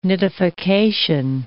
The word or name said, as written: nidification